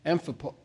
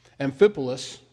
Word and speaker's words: Amphipolis: Now, when they had passed through Amph- uh, um, Amphipo- oh, I can't pronounce it. Amphipolis,